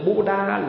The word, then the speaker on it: Buddha: B-U-D-D-H-A, phát âm là Buddha.